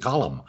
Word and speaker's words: Colm: We all said, how does he pronounce his name? And I finally said, how do you like people to pronounce your name? And he said, Colm.